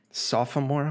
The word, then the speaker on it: sophomore: Sophomore, like a sophomore in college. Yeah, he's like the sophomore. There's two syllables, Alex.